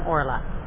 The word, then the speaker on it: Orla: But O.R., just first two initials of my name, Orla. So okay. Orla is a fairly common Irish name, but I certainly haven't met anyone in America with it.